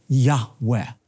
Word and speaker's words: Yahweh: Now the Lord, Yud-Heh-Vav-Heh, oftentimes pronounced a breathy Yahweh.